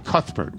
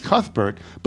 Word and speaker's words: Cuthbert: I say, hi, I'm Cuthbert. What do I get back? Cuthbert, Cuthbert, Colbert, Colbert. And I always have to smile and say, no, it's Cuthbert, but most people call me Cuth.